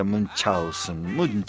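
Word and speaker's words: Münchhausen: Barón de Munchausen, o de Munchausen, como quieras pronunciarlo. En realidad se pronuncia Munchausen, que algún alemán me lo desmienta.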